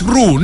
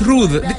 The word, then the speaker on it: Ruth: No es Ru, no. A las personas que tengan ese nombre, usted no puede decirle Ru, porque se molestan. Se molestan, no les gusta. Mejor dígale el apellido. Sí, es Ruth.